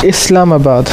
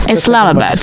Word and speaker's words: Islamabad: And just to be sure I'm pronouncing this correctly, It's Islamabad or Islamabad? – Islamabad. – Islamabad.